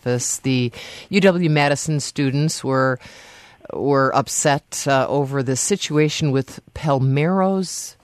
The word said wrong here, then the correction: Palermo's: Is that how you pronounce that? – Palermo.